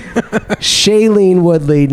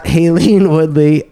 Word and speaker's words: Shailene Woodley: Aileen Woodley. – You forgot the S, buddy. – Shailene Woodley, not Haleen Woodley.